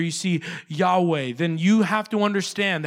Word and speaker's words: Yahweh: You see, when it's in all capital letters, that is the English translation for YHVH, which we believe it is pronounced Yahweh.